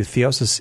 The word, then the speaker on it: theosis: I do want to point out to you, theosis is a three syllable word.